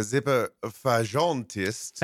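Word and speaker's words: Zippelfagottist: You complain about the singers in the choir, calling one of them Zipper Fagatist. I hope I'm pronouncing that correctly. Which translates to weenie bassoon player. Is that correct, Johann Sebastian Bach? – I believe it was Zipper Fagentist.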